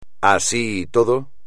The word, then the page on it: así y todo: espagnol